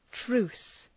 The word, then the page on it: truce: tru:s